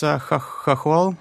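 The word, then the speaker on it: chokol (хохол): There's a Ukrainian word that you write about, kokol. I don't know if I'm pronouncing it right. – It's chokol.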